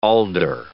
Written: alder

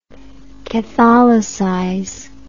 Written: catholicization

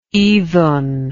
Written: idun

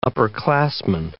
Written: upperclassman

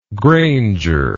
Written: grainger

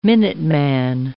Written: minuteman

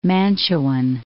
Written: Mantuan

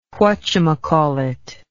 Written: whatyoumaycallit

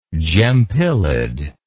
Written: gempylid

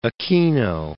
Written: Aquino

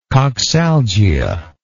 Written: coxalgic

